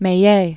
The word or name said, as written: Meillet